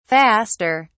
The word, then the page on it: faster: British English